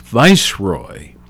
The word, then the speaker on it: viceroy: 1947, again on this date, Earl Mountbatten of Burma, appointed as last victory of India. I'm sorry, that's not right. I mispronounced a word. As last viceroy.